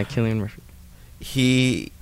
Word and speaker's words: Cillian Murphy: Yeah, Cillian Murphy.